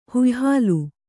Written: ಹುಯ್ಹಾಲು

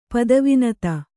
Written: ಪದವಿನತ

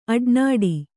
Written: ಅಡನಾಡಿ

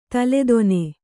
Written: ತಲೆದೊನೆ

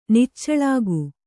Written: ನಿಚೋಲಕ

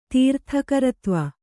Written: ತೀರ್ಥಕರತ್ವ

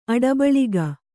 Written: ಅಡಬಳಿಗ

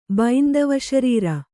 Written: ಬೈಂದವಶರೀರ

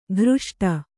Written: ಕೃಷ್ಟ

